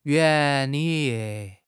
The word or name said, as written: 原理